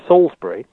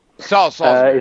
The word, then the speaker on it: Salisbury: Well, first of all, let me just correct your pronunciation. It's Salisbury. – Salisbury.